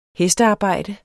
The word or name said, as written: hestearbejde